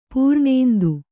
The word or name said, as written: ಪರನಿಂದೆ